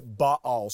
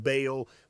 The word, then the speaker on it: Baal: Ahab's greatest offense to God was that he even encouraged Israel, the people of God, to worship a false god named Baal. Sometimes you hear it pronounced Baal, but really you'll hear me say Baal.